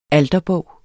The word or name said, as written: alterbog